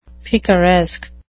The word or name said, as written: picaresque